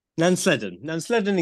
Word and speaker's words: Nansledden: 24 social homes being built in the, is it Nandlesden? Have I pronounced that right? – I'm going Nansledden. – Nansledden in Cornwall.